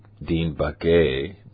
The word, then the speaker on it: Baquet: And Goodwin talks about the reaction, right, that the executive editor, the guy named Dean Baquet, B-A-Q-U-E-T, right, and that's how I pronounce it like that for all my friends from South Sacramento that are listening that normally don't speak English that well, right?